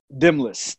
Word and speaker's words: Delmnus: Delmnus. The N is silent.